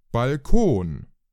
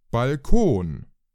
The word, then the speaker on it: Balkon: In English, we say balcony, and in German, it's Balkon. – It's originally a French word, and the way Germans pronounce it sounds very French to me, with that nasal N. – Balkon.